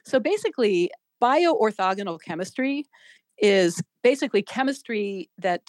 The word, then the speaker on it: bio-orthogonal: And how do you say this word? Bio-orthogonal reactions? – You got it.